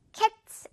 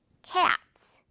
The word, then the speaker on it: cats: cats 아니고 cats. I have two cats.